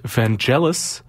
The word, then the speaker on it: Vangelis: Somebody texted into the booth during that the artist from the previous set, his name is pronounced Vangelis, so that's interesting.